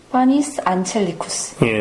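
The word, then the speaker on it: Panis Angelicus: 파니스 안첼리쿠스 파니스 안첼리쿠스.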